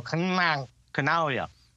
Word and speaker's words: Canaglia: Like, just instead of saying the G, stick your tongue on the top of your roof and go, Canaglia.